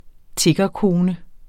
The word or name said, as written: tiggerkone